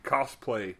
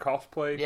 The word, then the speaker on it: cosplay: They look like 1987 Coplay. Cosplay. Is that how you say it? Cosplay? – Yeah. Yeah.